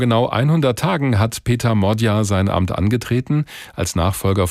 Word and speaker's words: Magyar: Der schreibt sich zwar Péter Magyar, also mit G und Y im Nachnamen, spricht sich aber Magyar aus.